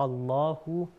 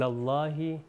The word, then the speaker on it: اللہ: اللہ، پھر الى اللہ، پھر ان اللہ، یہاں تینوں بار کیا ہوا ہے، ہم لوگوں نے اس کو موٹا تلفظ کیا ہے۔ جی بسم اللہ، کنٹینیو کریے۔